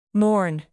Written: morn

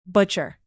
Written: butcher

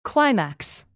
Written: climax

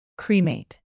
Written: cremate

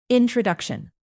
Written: introduction

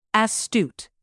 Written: astute

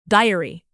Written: diary

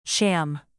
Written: sham

